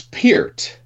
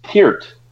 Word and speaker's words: Peart: It's Peart. Right? Like, it's supposed to be Neil Peart. – Yeah, I heard Geddy Lee explain it once, and he said, you know, ear, how you pronounce ear. – Right. – Peart, like ear in the middle.